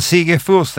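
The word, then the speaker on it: Sigge Fürst: Ja, men mitt uttal. – Jag uppfattar inte Furst riktigt. – Ja, men jag tar det snabbt. – Ja. Sigge Furst, han kom allra först. – Ja. – Men Sigge Furst är rätt.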